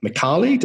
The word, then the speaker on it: Micali: So Algorand was founded in 2017 by Silvio Micali.